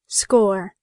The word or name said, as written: score